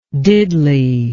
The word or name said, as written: diddley